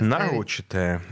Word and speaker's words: нарочитое: Нет, дело в том, что э, нарочатое использование феминитивов есть отличительная черта э, коверканье языка представителями ЛГБТ-сообщества. – Нарочитое правильное ударение. – Нарочитое. Вот. Это тоже правильно.